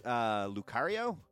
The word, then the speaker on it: Lucario: Illinois is Lucario. Did I say that right, Tyler? – Yes.